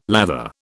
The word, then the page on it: lather: British English